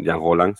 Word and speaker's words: Rollin: Se llama Jean Roland, se escribe Rollin, pero es un director bastante interesante.